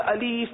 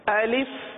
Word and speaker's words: الف: جیسے الف ہے, الف کو الیف پڑھا جاتا ہے یا زیادہ تر اسی طرح پڑھتے ہیں لوگ, الف یا الیف پڑھتے ہیں, تو یہ ادائیگی غلط ہے. درست ادائیگی یہی ہے الف.